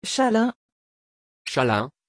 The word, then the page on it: chalin: French